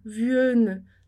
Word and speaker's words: vienne: Baguette com som de E, e a mesma coisa acontece para o il vienne, porque senão a gente falaria, se não tivesse esses dois N's ali depois do E, a gente falaria il vienne, vienne. Só que vienne não é pronunciável, fica ruim de pronunciar. Então ali o N ele está facilitando justamente essa questão da pronúncia, né? Para a gente poder dizer Viena, Viena, para a gente poder pronunciar o E com som de E, Viena, ok?